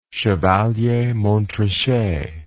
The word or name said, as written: Chevalier-Montrachet